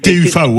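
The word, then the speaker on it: TÜV: Le TUFA, c'est ça, TU avec un umlaut ou T1V, exactement. – Voilà, exactement. Ça s'écrit TUVE, mais ça s'appelle le TUF.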